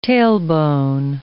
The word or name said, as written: tailbone